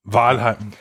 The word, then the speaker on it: Valheim: Und es ist, glaube ich, jetzt wie spricht man es aus? Ich sage einfach Valheim. Das ist jetzt Valheim. Ja. – Valheim, genau, Valheim.